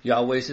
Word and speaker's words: Yahweh: This is kind of, Jehovah's actually a mispronunciation. – Interesting. – I mean, Jenny Lynn was joking about the song, there's no God like Jehovah. There isn't. – Yeah. – Because it's not his name, it's not in the Bible, right? But where did that come from? So Jehovah is kind of a mispronunciation. It's actually a Germanic pronunciation based off a Latin transliteration from Hebrew from like Yahweh.